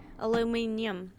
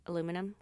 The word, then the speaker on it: aluminium: Aluminium. In Australia, they pronounce aluminum aluminium. – Yeah. – Can't you just say aluminum, guys? – Aluminum.